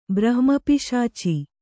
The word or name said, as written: ಬ್ರಹ್ಮಪಿಶಾಚಿ